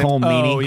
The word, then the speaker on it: Colm Meaney: Colm Meaney.